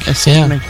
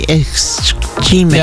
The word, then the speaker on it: ischemic: Most of the improvement came from fewer ischemic strokes. That's a hard word to pronounce. – It's ischemic. A rough one. Ischemic. Yeah. It's, some people will say ischemic.